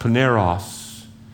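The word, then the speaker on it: paneros (πονηρός): This word evil is pronounced paneros. It's where we would get pornography. What he's saying is, God, until they're sanctified, I need you to protect them from themselves.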